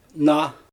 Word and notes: naa: Xyzyl

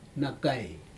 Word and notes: nakai: Aneityum

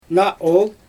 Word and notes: naa ool: Xyzyl

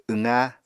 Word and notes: wqa: Apatani

